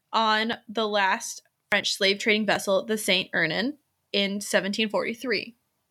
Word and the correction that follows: St. Ursin: St. Ursin. You're close. You said Ernan.